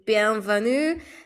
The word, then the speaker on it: bienvenue: Welcome to Living in Control podcast, or rather I would say bienvenue sur notre Living in Control podcast.